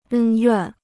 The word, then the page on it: 恩怨: ēn yuàn